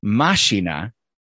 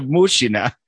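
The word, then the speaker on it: mashina (машина): And then I looked up the Russian word for machine, and it's mashina. And so I was like, oh, clearly Bert Kreischer went and just pronounced one letter wrong. And he said mashina instead of mushina.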